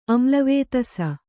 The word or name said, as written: ಆಮ್ಲವೇತಸ